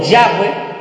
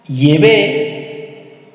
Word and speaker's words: Yahweh: También a Jehová, que es el Dios y Padre de Jesucristo, se le conoce por Yahweh, Yahvé, Yahvé. Hay diferentes maneras de pronunciar el nombre de Dios.